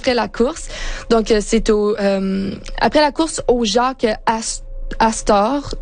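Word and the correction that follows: Jack Astor's: je l'ai prononcé vraiment français là, mais je pense Jake Astors.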